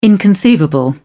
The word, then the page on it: inconceivable: British English